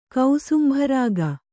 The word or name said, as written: ಕೌಸುಂಭರಾಗ